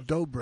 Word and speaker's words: dobro: Or a dobro. – I'm the fucking resonator. – Rhymes with dildo. Like a dildo rhymes with dobro.